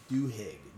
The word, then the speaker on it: Duhigg: Charles Duhigg.